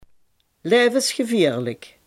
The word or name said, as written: levensgevaarlijk